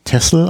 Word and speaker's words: Texel: Aber es wird eigentlich Texel ausgesprochen. Genau, Texel, genau.